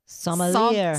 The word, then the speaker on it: Sommelier: that Sommelier. – Sommelier? Well, that's not how it's spelled. That bugs me now. I'm the phonics girl, you know. Anyway, must be a French word or something.